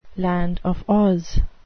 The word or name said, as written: land of Oz